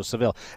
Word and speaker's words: Saville: Before we get into this, Isaiah, I come from Canada, and we say your name differently out west than we do out east, and I want to get the pronunciation right from you as far as how we say the last name. – It's Saville. – Okay. Because Saville is the way that I've always pronounced it, and I didn't want to just default to that. So, Saville.